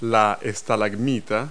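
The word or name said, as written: stalagmite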